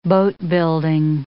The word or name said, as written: boatbuilding